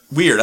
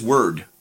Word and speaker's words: Wyrd: It's spelled W-Y-R-D. So I thought it was weird. – I thought it was weird, but in the game, it is Word. They say it as Word.